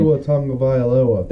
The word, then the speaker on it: Tua Tagovailoa: Arizona could not cover -4 versus the Dolphins because Tua Tagovailoa, however you say his name. Tua Tagovailoa. – There you go.